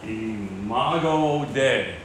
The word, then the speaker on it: Imago Dei: It's called Imago Dei, is how it's pronounced.